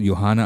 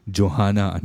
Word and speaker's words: Johanna: And it's called Johanna Under the Ice. Or for some of you who like to pronounce the J, Johanna Under the Ice.